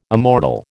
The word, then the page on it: immortal: American English